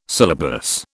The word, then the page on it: syllabus: British English